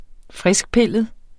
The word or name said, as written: friskpillet